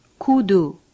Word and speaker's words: 구두: A U sounds like the U in rude. For example, 구두.